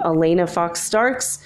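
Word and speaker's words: Alanna Fox Starks: That's spelled with two N's, A-L-A-N-N-A, and then Fox, just like it sounds, like the animal, F-O-X, no relation to the network, and Starks, S-T-A-R-K-S. So it's like a star, K-S, or Stark, like Iron Man with an S at the end.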